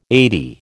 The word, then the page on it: eighty: American English